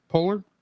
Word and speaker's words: Poehler: Polar.